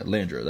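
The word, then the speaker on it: De Landro: Yeah, Landro, that's how you pronounce it.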